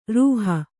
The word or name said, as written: ರುಹೆ